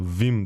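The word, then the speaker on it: Wim: Е, аз го наричам Уим, ама реално да, да, Вим да. трябва да е правилното произношение.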